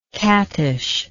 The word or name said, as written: cattishly